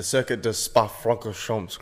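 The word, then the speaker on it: Circuit de Spa-Francorchamps: Now, go for it. Pronounce it. – The Spa Francorchamps Grand Prix. – Or the Circuit de Spa Francorchamps. – The Circuit de Spa Francorchamps Grand Prix. – That's the one. Well done.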